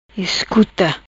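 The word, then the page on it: Iscuta: Patuá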